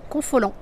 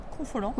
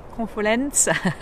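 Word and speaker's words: Confolens: Confolant. – Confolant.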